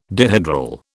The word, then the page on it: dihedral: British English